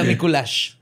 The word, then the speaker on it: Mikulax: L-A-X. – Mikulax. – Mikulax. – Mi perro. – Pero se pronuncia Mikulash.